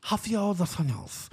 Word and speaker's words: Rafael dos Anjos: You got to say it like your tongue is swollen. Rafael dos Anjos. Because it's just sexy like that.